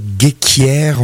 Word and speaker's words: Ghekiere: Des nouveautés, donc l'album de l'année dernière de Abel Gheikhiere, on va le prononcer comme ça, ça s'écrit G-H-E-K-I-E-R-E.